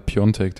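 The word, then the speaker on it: Piątek: Die Sviatek geschrieben wird, aber Sviontek, äh, Sviontek ausgesprochen wird, wie der ex-härter Fußballer äh, Piontek, der auch Piatek geschrieben wird.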